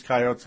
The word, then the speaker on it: Coyotes: And when the Wild score early at home against the Phoenix Coyotes, regardless of how good the Phoenix Coyotes have been, Coyotes, I'll just say it that way from now on. I've been saying it wrong.